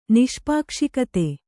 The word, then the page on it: ನಿಷ್ಪಾಕ್ಷಿಕತೆ: Kannada